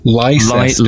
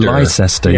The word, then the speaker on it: Leicester: Yeah, I don't like the way Leicester is spelt. – Leicester. Leicester, yeah. yeah. Leicester. Strange, isn't it?